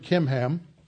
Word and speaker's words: Kimham: But I have a servant, Kimham. That's how it's spelled, actually, I don't know why they transliterated as a CH. It's a K.